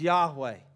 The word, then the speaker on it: Yahweh: We pronounce it now as Yahweh.